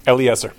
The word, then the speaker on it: Eliezer: Oh, speaking of how you say things, Eniaj, how do you say Yudkowsky's first name? – Eliezer. – Eliezer? – Yes.